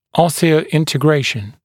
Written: osseointergration

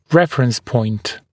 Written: reference point

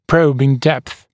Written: probing depth

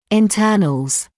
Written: internals

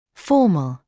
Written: formal

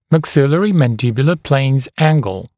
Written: Maxillary Mandibular Planes Angle (MMPA)

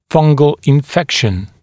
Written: fungal infection

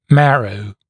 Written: marrow

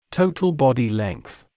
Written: total body length

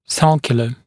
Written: sulcular

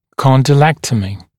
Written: condylectomy

